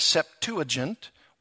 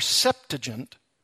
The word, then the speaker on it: Septuagint: The Greek translation of the Hebrew Old Testament is called, either pronunciation is acceptable, the Septuagint or Septuagint.